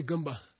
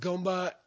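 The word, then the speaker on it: gompa: Then there's a Gumba, say Gumba, which is mispronounced Gomba.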